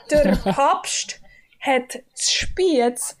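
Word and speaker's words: Der Papst hat spät Speck bestellt: How do you pronounce the DR? – Yeah. – Doctor Papst. It's like Dr. Pepper, but it's German. Dr. Papst. – And the ST is a st. And the SP is sp.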